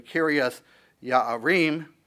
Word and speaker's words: Kiriath Jearim: In fact, you're going to see that Kiriath Jearim, we would probably pronounce it more accurately, Kiriath Yaarim, is actually a city of the sons of Judah.